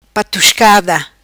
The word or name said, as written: Patuscada